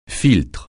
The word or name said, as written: filtre